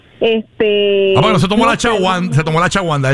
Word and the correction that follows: Ashwagandha: él se la tomó ese día. – Ashwagandha se llama.